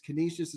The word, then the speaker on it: Canisius: Canisius is how you pronounce the school.